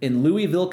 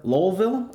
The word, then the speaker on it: Louisville: Hirschen has officially acquired Kentucky Kingdom in Hurricane Bay in Louisville, Kentucky, or Lowellville, I believe is how you would say it if you're from around those parts. I probably butchered it. It's kind of like with Dollywood, though. There's Sevierville, which looks like Sevierville. So maybe I got Louisville right. Lowellville. Is that right? – It sounds right.